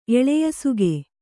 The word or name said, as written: ಎಳೆಯಸುಗೆ